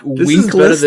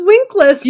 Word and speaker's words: Winkless: It's spelled with two S's, so that's not Winkles. That's Winkless.